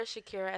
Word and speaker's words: Shakira: Like it's spelled like it sounds, but I get it. People, we spell some shit however we want it. So that's all right. Underscore Shakira Shakira Nicole underscore on Instagram.